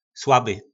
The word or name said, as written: slaby